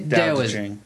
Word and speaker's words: Tao Te Ching: Tao Te Ching.